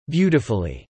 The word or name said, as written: beautifully